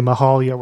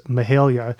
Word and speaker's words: Mahalia: So I immediately got the pronunciation correction there, because I think a lot of people are inclined to say Mahalia when they see her name, but it is Mahalia.